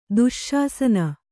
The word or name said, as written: ದುಶ್ಶಾಸನ